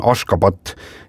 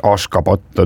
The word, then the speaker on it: Ashgabat: Jaa, selle pealinna tänapäevane õige kirjapilt ja hääldus on Ashgabat ja siis 1991.